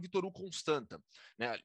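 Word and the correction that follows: Constanța: Me corrija a pronúncia, por favor, porque eu tenho certeza que eu não falei corretamente o nome do clube. Mas é, como é que é? – É Vitoru Constança.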